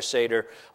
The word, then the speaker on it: Seder: So when you leave here this morning, you can kind of repeat to yourself, Nader, Vader, Passover Seder, like that. Maybe where no one else can hear you, but you'll have the pronunciation down perfectly, a great way to learn the word Seder.